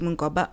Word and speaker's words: Mungoba: My name is really the way you say it is the way you spell it. Otilia Tutu Mungoba, O T H I L I A Tutu T U T U Mungoba M U N G O B A.